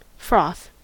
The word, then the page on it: froth: American English